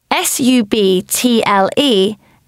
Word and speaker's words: subtle: S U B T L E is subtle.